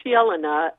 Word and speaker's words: Jelena: Jelena, J-E-L-E-N-A. – Yeah, it's pronounced Yelena. – Yelena? – Like J-E-L-A-N-A.